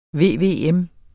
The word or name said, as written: VVM